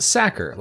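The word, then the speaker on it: Sachar: It should be pronounced Sacker, like someone who tackles quarterbacks, or someone who stuffs potatoes into sacks.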